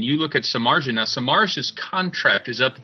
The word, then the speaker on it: Samardzija: Jeff Samardzija, see, I do know how to pronounce it out there, folks.